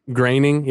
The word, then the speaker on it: Groening: So one day she literally like took Pendleton as a young man and walked him up to the door of Matt Groening from the Simpsons. I guess that's how you pronounce his name. It's spelled weird. – Groening.